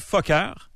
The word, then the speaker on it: Fokker: C'est Fokker, c'est pour les anglophones. – Ben oui, mais c'est comme ça qu'on devrait le prononcer.